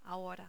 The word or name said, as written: ahora